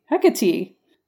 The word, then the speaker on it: Hecate: Hecate.